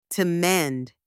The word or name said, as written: to mend